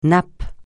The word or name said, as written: nappe